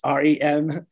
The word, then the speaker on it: Remezcla: I'm pronouncing it, rolling my R's, but it's Remezcla, has a great portal there of information on news and entertainment around Hispanic Heritage Month.